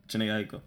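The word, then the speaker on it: Jhené Aiko: And he dropped Contradictions with Janae Aiko. I guess that's how you say her name. – Aiko. Don't disrespect my girl like that ever again. Janae Aiko.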